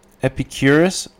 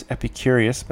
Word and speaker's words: Epicurus: Epicurus, which, by the way, I always thought it was Epicurious, and that's the food website, has nothing to do, it's Epicurus,